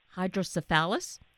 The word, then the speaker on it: hydrocephalus: And for yourself, as a child, having to go through dealing with the hydrocephalus, that's the correct pronunciation, uh, right? Yes.